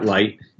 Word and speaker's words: Lyte: So I'm the head of business and corporate development at Light. It's spelled L-Y-T-E for those of you following along at home.